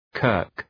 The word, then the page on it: kirk: kɜ:rk